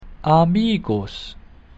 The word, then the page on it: amicus: Latin